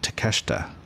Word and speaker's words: Takeshita: Was it Takeshita? – Takeshita, yeah.